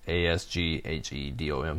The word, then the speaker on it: Asghedom: The release said Hustle, whose real name was Ermas Shandon. I'm sorry I pronounced it. His first name is Ermias, and the last name is Asghedom.